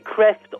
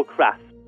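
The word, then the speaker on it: Craeft: The book, again, is called, is it Kreft? Is that the right pronunciation? – Yeah, Kreft or Kraft.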